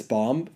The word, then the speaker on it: bomb: And you have to defuse this bomb. So the person wearing the VR... – Are you pronouncing the B in bomb? – Bomb. – Don't pronounce the B, please. – Wait, bomb. – Bomb. B-O-M.